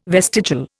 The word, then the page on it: vestigial: Indian